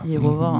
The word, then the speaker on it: Jehova: Ni vet att man måste uttala det lite annorlunda. Jehova.